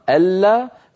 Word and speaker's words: ألا: لأن حفصا رحمه الله كان يتبع رسم المصحف فطالما أن الصحابة كتبوها أمام رسول الله بأبي وأمي هو على نية الإدغام ألا فلا يوقف على النون لأنها ليست في الخط فنقول ألا ألا هكذا ننطق هذه الكلمة في كلمة ألا تقاتل وفي كلمة ألا نقاتل.